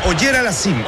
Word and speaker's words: Auger Aliassime: Puede jugar contra Autotem, que es el local, pues es, disculpe la pronunciación, Auger Aliassime. – Es Auger Aliassime, el canadiense. Félix Auger Aliassime.